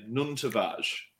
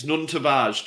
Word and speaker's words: Nuno Tavares: That's the correct way to say his name, Nuno Tavares. – Can we call him Nuno Tavares, the same as everybody else? – Well, his name's Nuno Tavares, champion.